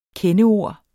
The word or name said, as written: kendeord